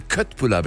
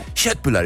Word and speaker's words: Köttbullar: wenn ich bei Ikea stehe und die Leute Köttbullar bestellen. Das sind ja Köttbullar, ich bin Halbschwede, die Aussprache ist ja fürchterlich.